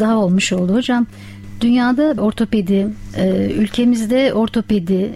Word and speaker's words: ortopedi: Çünkü toplumda bunu birçok kere insanlar farklı telaffuz edebiliyor ama aslında kullanılması gereken kavram ortopedi kavramıdır. Ortoped dediğimiz zaman iki kelimeden meydana gelen Latince bir kelimedir aslında.